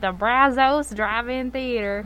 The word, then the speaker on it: Brazos: It's Brazos. Brazos. – But that's an O. – I know, but it's pronounced Brazos.